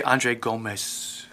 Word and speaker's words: Andre Gomes: Especially if yeah, I did like the pronunciation thing, by the way. I should just say Andre Gomes.